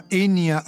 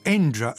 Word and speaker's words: Enja: Dialogues, dialoghi, pubblicato per l'etichetta tedesca Enya Records o Enja Records, se vogliamo pronunciarlo con un tocco più jazzistico.